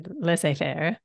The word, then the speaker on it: laissez-faire: We have been very, is it laissez-faire? How do you say it? – Yes. Well, I am from a partially French-Canadian speaking country, so we'd say laissez-faire.